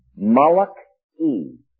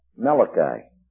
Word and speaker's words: Malachi: All right, so he says, "And I sent and symbolized it by Malachi," the Hebrew now, Malachi, or as we pronounce it, Malachi.